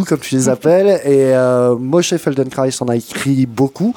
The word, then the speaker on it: Moshe: Moshe, je le dis bien. – Moshe.